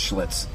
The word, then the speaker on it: Schlitz: and it's Schlitz. Yeah. Not cool. – Are you schvitzing over there? – It rhymes with slitch.